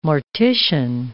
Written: mortician

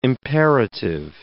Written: imperative

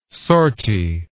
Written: sortie

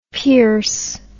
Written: Pearse

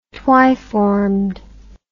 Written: twiformed

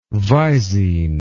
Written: Visine

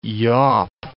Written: yaup

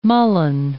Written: mullen

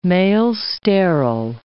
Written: male-sterile

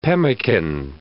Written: pemican

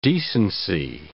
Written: decency

